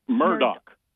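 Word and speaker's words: Murdaugh: Well, we didn't know that you pronounced Murdaw, M-A-R, Murdaw with no K is pronounced Murdoch.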